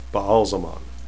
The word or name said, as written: baalzamon